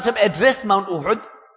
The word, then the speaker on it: Uhud: Many people pronounce it as Uhud. It is Uhud.